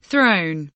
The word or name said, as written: throne